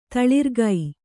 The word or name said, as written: ತಲಾರಿಕೆ